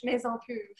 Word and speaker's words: Maison Pur: And if you all that are listening, we mentioned Molly of Maison Pur. Am I saying it right? – Yeah, Maison, I mean French, Maison Pur.